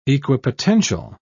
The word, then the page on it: equipotential: British English